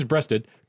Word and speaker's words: Breasted: James Breasted coined the term, it's Breasted. I actually looked up the pronunciation because I've been getting angry at myself at mispronouncing a lot of things.